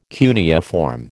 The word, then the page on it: cuneiform: American English